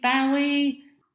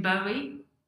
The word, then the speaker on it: Bowie: And today my conversation is with a lifelong David Bowie fan. So, hi welcome to All About You podcast. – Hello, Sheila. How are you? – I'm fine, thank you. So, my first question, David Bowie.